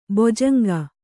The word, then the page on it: ಬೊಜಂಗ: bojanga